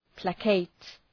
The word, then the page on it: placate: ‘pleıkeıt